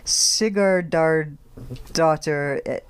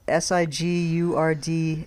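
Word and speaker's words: Sigurðardóttir: It's Yrsa Sigurdardóttir. It's very long. Sigurdardóttir.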